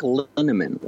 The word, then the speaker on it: Linnemann: My name is Mike Linneman. Last name rhymes with cinnamon, and I'm very easy to find online when people search for me.